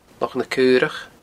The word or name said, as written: Loch na Caorach